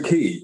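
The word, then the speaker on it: Ki: Yeah, so Key, which is, you know, for the listeners, it's spelled K-I, Key.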